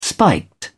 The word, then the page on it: spiked: British English